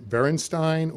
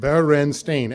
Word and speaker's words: Berenstain: No, it is not spelled Bernstein, Berenstain, or Bearstain. It is pronounced Berenstain, as in coffee stain or jello stain.